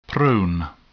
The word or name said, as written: prone